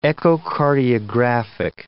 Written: echocardiographic

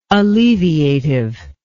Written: alleviative

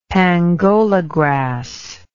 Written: pangola grass

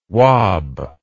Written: wab

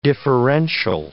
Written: differential

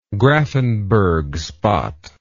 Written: grafenberg spot

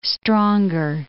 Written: stronger

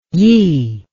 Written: yi

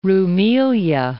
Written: Rumelia

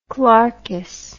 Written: clerkess

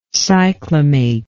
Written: cyclamate

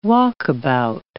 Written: walkabout

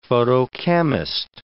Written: photochemist

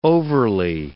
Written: overly

